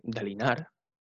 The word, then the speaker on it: Dalinar: Por ejemplo, si es una palabra de tres, eh, una, sí, un nombre de tres sílabas como Dalinar o Gavilar, se suele poner, o Caladin o Adolin, se suele, suele hacerla a la gente como esdrújula, es decir, poniendo el énfasis en la primera, en la primera sílaba.